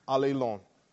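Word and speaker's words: allelon (ἀλλήλων): The person said, one another is two words in English, but it's only one word in Greek pronounced allelon.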